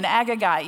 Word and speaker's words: Agagite: We know that Haman is an, I don't know how to say that, y'all, so we're going to, thank you, an Agagite. You got that? We're going to put an extra syllable in there.